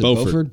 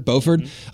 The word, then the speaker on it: Beauford: Beauford. – Beauford of Dave Matthews Band.